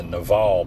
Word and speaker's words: Naval: It's the Naval podcast. I know. His name is spelled N-A-V-A-L, like naval, like the Navy, naval.